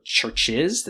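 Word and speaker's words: CHVRCHES: Now it is my great honor to be joined by Lauren Mayberry, the lead singer of the trio Churches, that's spelled with a V, C-H-V-R-C-H-E-S.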